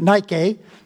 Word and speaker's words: Nike: The word victory is translated in the New Testament as victory or overcome is the Greek word Nike. It's pronounced a little different than the Greek, but Nike.